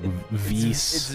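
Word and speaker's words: Weiss: I thought you said Whis. Didn't you say his name like dumb somehow? It's like Vice.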